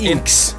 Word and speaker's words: INXS: E Uttalar jag det rätt? Inks.